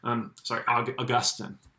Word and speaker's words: Augustine: Augustine is the right pronunciation.